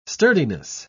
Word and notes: sturdiness: British English

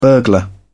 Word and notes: burglar: British English

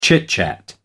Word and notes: chit-chat: American English

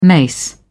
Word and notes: mace: British English

